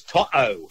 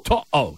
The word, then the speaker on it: To'o: It's To'o. – To'o, yeah, that's right.